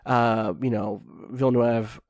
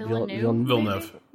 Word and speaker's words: Villeneuve: And I think that, Villeneuve, that's not how you pronounce it, is I never get his name right. – Villeneuve.